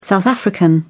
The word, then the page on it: south african: British English